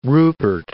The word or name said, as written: Rupert